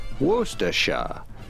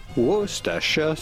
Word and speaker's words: Worcestershire: And finally, the typical British pronunciation, Worcestershire. Worcestershire sauce.